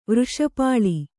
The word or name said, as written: ವೃಷಪಾಳಿ